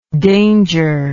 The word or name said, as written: dangerless